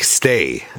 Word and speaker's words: Stage: Äh, und er spricht sich Stay.